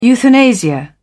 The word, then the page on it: euthanasia: British English